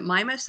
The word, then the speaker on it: Mimas: I tend to pronounce it Mimas. I think either one is correct. Either pronunciation is correct.